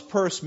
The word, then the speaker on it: Peirce: And he promoted an American philosopher by the name of Charles Peirce. It's spelled P-E-I-R-C-E, but pronounced Peirce,